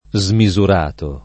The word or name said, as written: smisurato